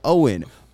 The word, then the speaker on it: Owyn: I have been pronouncing their name wrong, and that is on me. It says in big yellow letter, pronounced like Owen, O-W-Y-N, is a fantastic 100% plant-based protein shake that gives you nutrition that works just as hard as you.